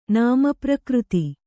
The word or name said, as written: ನಾಮಪ್ರಕೃತಿ